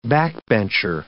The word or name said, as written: backbencher